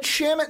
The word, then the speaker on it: Shamet: Which you said Shamet, and then I'm like, that makes sense. Kind of like shame. You're not going to say sham. – Mm-hmm. – It's shame. So Shamet going to the Wolves at 25.